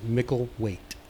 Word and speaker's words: Micklethwaite: And now you all know how to pronounce it. So right. All the people that the pronounce it wrong, silent. Yes, the Micklethwaite can go away. We don't expect you to spell it right, but at least pronounce it right, people. – Yeah. It's easy enough once you learn how to pronounce it, right? Micklewaite. no TH. It just ignore it. – So it's like it's not even there. – Yeah, just silent. Ignore it.